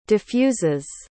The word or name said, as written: diffuses